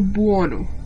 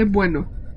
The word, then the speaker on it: Buono: Kenneth Bianchi y Angelo Buono. Se escribe Buono, pero si se, se dice bueno.